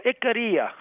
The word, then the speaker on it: Ikaria: Tell me how to pronounce it one more time. – Yeah, it's musical. Ikaria.